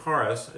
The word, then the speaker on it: charis: One of the meanings of the Greek word charis, it's actually charis, but it's spelled C-H-A-R-I-S, it was translated forgiveness, but actually it's favor.